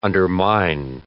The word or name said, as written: undermine